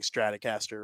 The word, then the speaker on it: Stratocaster: I think I'm saying Stratocaster, right? I think that's what it was called. – You are saying it correctly.